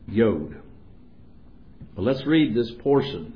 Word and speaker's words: Yod: It says J-O-D, but the J in Hebrew is pronounced like you would yes. So it's the Yod.